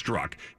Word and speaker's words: Strzok: Peter Strzok, I know his name looks funny, but it's got a Z in it, but it's pronounced Strzok.